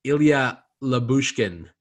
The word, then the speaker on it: Ilya Lyubushkin: And I know you guys told me how to pronounce this right before the show, but Ilya Lyubushkin. – There you go.